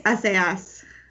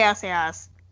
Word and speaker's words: Assayas: I think it's Assayas. – Assayas. – It's Olivier Assayas.